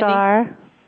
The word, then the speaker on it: Strgar: Strgar.